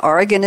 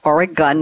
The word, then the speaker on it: Oregon: And Oregon is pronounced Oregon, not Oregon. I had to learn that when I moved there.